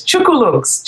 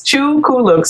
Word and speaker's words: Chookooloonks: My blog is Chookooloonks, Chookooloonks, C H O O K O O L O O N K S, Chookooloonks.